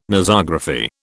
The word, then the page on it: Nosography: British English